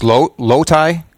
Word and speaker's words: Loti: Now, you come to us from webmaster John Kelly, who does the, now is it pronounced Loti, L-O-T-I dot com? – Yes.